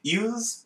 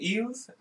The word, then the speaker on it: Elwes: Ewes, I think. Ewes. ELWES.